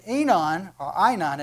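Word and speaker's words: Aenon: And John also was baptizing in Einon, it actually is the way you pronounce it, near Salim, because there was much water there, and they were coming and were being baptized.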